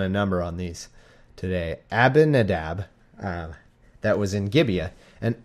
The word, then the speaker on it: Abinadab: The sons of Abinadab drave the new cart, and they brought it out of the house of Abinadab, which I just want to say like Ahmadinejad every time, so that I get my brain like skips like a record when I start to say Abinadab. I want to put in another syllable.